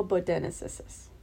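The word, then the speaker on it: bodoensis: Homo bodenensis. – I think she nailed that pronunciation.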